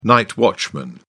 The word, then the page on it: night watchman: British English